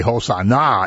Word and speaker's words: Hosanna: And if you say Hosanna, it means come and save us now. It's like emphasis on when you accent the last syllable.